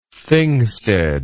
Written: thingstead

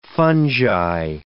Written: fungi

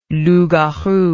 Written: loup garou

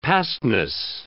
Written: pastness

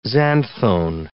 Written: xanthone

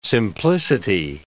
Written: simplicity

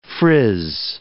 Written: frizz

